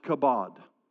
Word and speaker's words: kabod: I believe that what we have experienced is described in this Hebrew word pronounced kabod. And kabod, sometimes it's translated as glory, but the Hebrew word in the Old Testament for that is kabod, and it means the heavy presence of God.